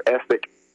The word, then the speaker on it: Essiac: C-A-I-S-S-E, if you spell that backwards, it's E-S-S-I-A-C, and that's Essiac, or Essek,